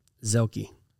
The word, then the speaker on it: Zielke: Zielke.